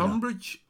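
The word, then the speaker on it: Tonbridge: Tunbridge, the town Tunbridge, very historical. It's got a lovely castle, the remains of a castle. It's spelt with an O, so Tunbridge or Tunbridge.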